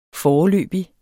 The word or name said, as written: foreløbig